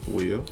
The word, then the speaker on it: weal: Wheel. – That is W-E-A-L. Wheel. And it just means happiness.